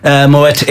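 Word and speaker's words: Moët: If anyone's wondering about the pronunciation, it's because there's a two little dots over the E, which means you pronounce both syllables of the word, so it's Moet.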